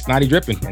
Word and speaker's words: Snotty Drippin: Just take Scottie Pippen and spell it so it sounds like Snotty Drippin, and that's me on Twitter.